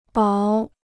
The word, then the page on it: 薄: báo